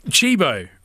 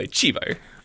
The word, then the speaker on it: cibo: And I'm like, Chibo. – Chibo, right? – Yeah, right? – Now. – C-I-B-O, Chibo.